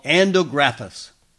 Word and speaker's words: Andrographis: This plant is called Andrographis.